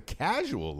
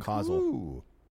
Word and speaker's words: causal: Study finds a causal link. – Causal. – Ooh. – A what? – A causal? – Causal link, like causation. – It's spelled like casual.